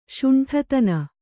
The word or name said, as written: ಷಂಡತನ